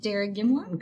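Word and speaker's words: Derrygimlagh: In Derrigamla? – Good job. – Oh, thank you. – She's got the pronunciation down.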